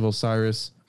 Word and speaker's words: Osiris: To the ancient symbolist, this signified the material universe whose mysteries were revealed by the cats or panthers, the priest of Osiris.